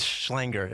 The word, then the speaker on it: Schlenger: It's Schlenger. It rhymes with hanger.